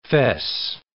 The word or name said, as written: fesse